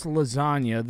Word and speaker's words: lasagna: When you pronounce lasagna, the G is silent, although it wouldn't have the y sound at the end if the G wasn't there.